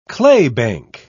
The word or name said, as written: claybank